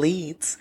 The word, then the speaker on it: Leeds: So our lesson begins on April 8th, 2014 in Leeds, which is in West Yorkshire.